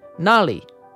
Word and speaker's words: Nali: The word of the day is Nali. Nali. Nali is the Yaru word for true.